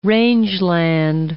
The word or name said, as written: rangeland